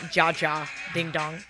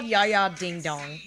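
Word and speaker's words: Ja Ja Ding Dong: It's not, FYI, it's not Jia Jia ding dong. It's Ya Ya. The J is silent.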